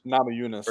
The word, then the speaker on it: Namajunas: Namajunas.